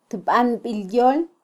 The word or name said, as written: tbanbilyol